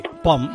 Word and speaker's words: pomme: A ver, se escribe P-O-M-M-E. Pom, manzana ves tú manzana y, se, y, se, lee pom.